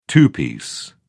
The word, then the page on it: two-piece: British English